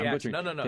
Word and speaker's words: Cantinflas: Cantinflas.